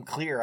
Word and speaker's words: Clear: It really sounds like they're pronouncing her name as Clear. So I looked it up on Wikipedia, and sure enough, her fucking name is goddamn Clear, I guess.